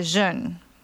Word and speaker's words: Zhen: And in Chinese, we call this area Zhen, and that's spelled Z-H-E-N.